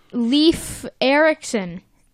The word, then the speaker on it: Leif Erickson: So, SpongeBob's like, hey, it's Leif Erickson. Did I pronounce that right? – Yes.